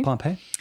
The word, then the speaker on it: Pompey: P-O-M-P-E-Y, Pompey. – Mm-hmm. Pompey.